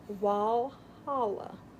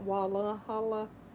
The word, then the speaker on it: Walhalla: Walhalla. There's no, it's not Walla Halla.